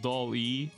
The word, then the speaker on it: DALL-E: I I think you pronounce it Doll E or like Doll E two, OpenAI's image generation model.